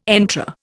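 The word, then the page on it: entrée: Indian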